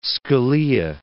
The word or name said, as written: Scalia